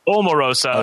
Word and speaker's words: Omarosa: I think we have established it's Omarosa, not Omarosa. – Yeah, I was right. – Like back in the day, it's Omarosa.